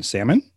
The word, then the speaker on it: salmon: Would you also pronounce the L in salmon? – Ugh, no.